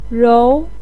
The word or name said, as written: rou2